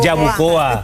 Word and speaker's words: Yabucoa: ¿Me equivoqué o lo dije bien? Lo dije bien, Yabucoa.